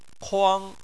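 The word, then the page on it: kuang: tone 1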